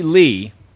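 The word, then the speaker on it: Li: Lucy Lee, and I think that's how you pronounce it. The last name is L-I.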